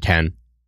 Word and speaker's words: Kehn: So it is Kane? – The pronunciation. – Interesting. – Yeah, it is Kane. – Why didn't you warn K-E-H-N. – Us? – K-E-H-N. I'm saying Ken.